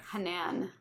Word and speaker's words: Hanan: Hanan.